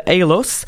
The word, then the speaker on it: Haelos: Euh, le groupe s'appelle Halos. Ça s'écrit H-A-E-L-O-S.